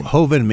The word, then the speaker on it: joven: If it's a Jovan, J-O-V-E-N, how would you pronounce that? – Jovan? Hoven maybe. – Yeah. – If it's Spain. – Hoven means that it didn't spend any time in oak. – Yeah, okay. – Yeah, J-O-V-E-N.